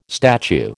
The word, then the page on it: statue: American English